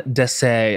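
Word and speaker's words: Dessès: She was in, I don't know how to pronounce the designer's name, but it's Jean Desses, I'm going to say, D-E-S-S-E-S. Okay. As someone mm-hmm. who speaks French, am I getting that D-E-S-S-E-S? – Right? Yeah.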